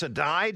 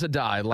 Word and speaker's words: Adai: I mean, it looks like a day, but it's Adai. – It is Adai.